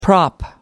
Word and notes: prop: American English

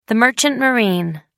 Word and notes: merchant marine: British English

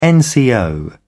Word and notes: nco: British English

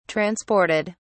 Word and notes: transported: British English

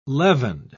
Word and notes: leavened: British English

